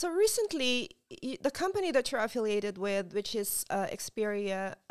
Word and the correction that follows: Experior: did I pronounce it right? – Experior Financial Financial Group, they reached a huge milestone by hitting 4,000 licensed agencies across North America.